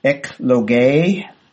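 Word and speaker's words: ekloge (ἐκλογή): So, ekloge, so a long E there, that's how that one's pronounced.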